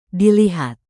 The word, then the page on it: dilihat: indonesian